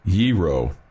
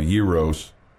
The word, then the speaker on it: gyro: Gyro, right? Am I saying that right? Gyro would apply to a single sandwich, as in I want a gyro, while gyros would be the correct pronunciation if you were to say I love gyros, Greek experts said.